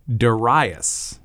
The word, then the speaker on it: Darius: Um, and when we read it to our Western eyes, it looks kind of like Darius. It looks like Darius. It seems like the uh, the the everyman way of saying it. Right, but it's not. Um, it's actually Darius.